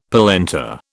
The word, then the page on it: polenta: British English